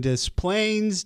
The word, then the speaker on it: Des Plaines: Another one coming in from John and Eileen in Des Plaines. Did I say that right? Illinois? – Sure.